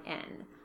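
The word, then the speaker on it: Mondawmin: I purchased some Neighborhood Fiber Company Rustic Fingering at Stitches 2014 in the colorway, and I know I'm mispronouncing this, Mondomin. It's Mondowmin.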